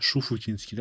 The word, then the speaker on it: Шуфутинский: Шафутинский и... – А. А, Шуфутинский, да, по-моему, кстати, правильно говорить.